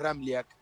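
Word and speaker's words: Ramljak: Es un poco complicado porque tiene tres consonantes juntas y la J se pronuncia como una I. Bien pronunciado el apellido es Ramliak.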